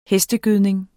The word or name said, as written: hestegødning